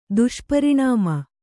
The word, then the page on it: ದುಷ್ಪರಿಣಾಮ: Kannada